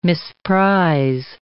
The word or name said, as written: misprize